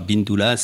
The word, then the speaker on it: Bindoula: Alors, pour ceux qui voudraient savoir, Bindoula, ça s'écrit comme ça se prononce, hein, B-I-N-D-O-N-L-A. – D-O-U-L-A. – D-O-U-L-A, pardon.